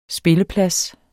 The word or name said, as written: spilleplads